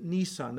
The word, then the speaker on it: Nisan: Nisan, it's spelled N-I-S-A-N. Some pronounce it Nisan. That's a car. This is a Jewish month, Nisan.